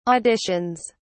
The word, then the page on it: auditions: British English